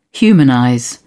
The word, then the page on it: humanize: British English